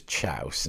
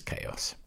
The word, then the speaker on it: chaos: I assumed it was chouse, and it was just a word that adults didn't say. Turned out it was chaos. straight from the Greek, as I later discovered.